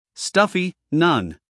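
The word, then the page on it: stuffy: uk /ˈstʌfi/; en-us /ˈstʌfi/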